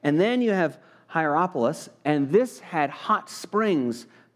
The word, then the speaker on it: Hierapolis: There was Laodicea, there was Colossae, which is the book of Colossians, and then a place called Hierapolis is the way it's pronounced, Hierapolis.